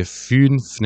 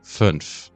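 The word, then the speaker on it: fünf: ولی اگه پس از همین او املات دو تا حرف بی صدا بیاد مانند واژه 5 در زبان آلمانی، تلفظش دیگه فینف نمیشه بلکه میشه فنف، این صدای کوتاه.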